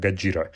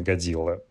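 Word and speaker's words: Гаджира (ゴジラ): Э, по-японски оно произносится как «Гаджира» а не Годзилла.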